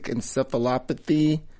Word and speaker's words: encephalopathy: Brother's got brain damage, toxic encephalopathy.